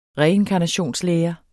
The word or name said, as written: remedium